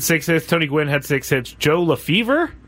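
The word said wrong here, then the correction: Lefebvre: Joe LaFay. – Oh. – It's pronounced LaFay.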